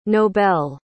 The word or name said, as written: nobel